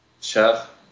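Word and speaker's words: Kjær: How do you say Simon's last name? – Chef. – What is it? – Chef, if you want to say it in his words.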